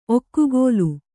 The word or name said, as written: ಒಗ್ಗೋಲು